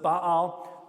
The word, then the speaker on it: Baal: Baal. I'm talking B-A-A-L, right? That's how I pronounce it. You might call him Baal.